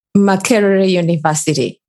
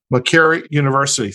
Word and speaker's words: Makerere University: Makerere University. – Makerere University.